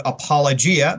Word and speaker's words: apologia: For instance, in uh, uh, 1 Peter 3.15, that's where we get the word apologia. That's the Greek uh, uh way of pronouncing it.